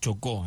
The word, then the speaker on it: Chocó: es el Chocó, así se pronuncia.